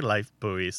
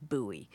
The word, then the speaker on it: Lifebuoy: In this country, the predominant pronunciation is buoy, like Nicole says. But the reason that we don't say Lifebuoy soap is that Lifebuoy soap was invented in 1895 by the Lever Brothers Company in the United Kingdom. So that's why we say Lifebuoy rather than Lifebuoy.